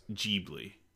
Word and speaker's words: Ghibli: Everyone I know here says Ghibli. – Right. – Or Ghibli, right. Yeah. – Okay. – Soft G.